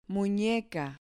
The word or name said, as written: muneca